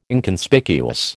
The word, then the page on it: Inconspicuous: American English